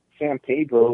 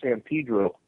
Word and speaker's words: San Pedro: I live in L.A., and I just wanted to let you know it's not pronounced San Pedro. It's pronounced San Pedro.